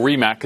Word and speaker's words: Rimac: But, but Robert, on the Rimac, or Rimac, as you correctly said and corrected me, despite being here in Europe on the pronunciation there.